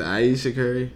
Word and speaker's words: Ayesha Curry: Ayesha Curry.